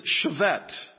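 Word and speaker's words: Chevette (שבט): It's the Hebrew word shevet. I think of the Chevrolet Chevette years ago. Has nothing to do with it, but if you want to know how to pronounce it, it's Chevette. S-H-E-V-E-T.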